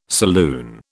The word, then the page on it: saloon: British English